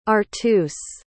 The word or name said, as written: arthus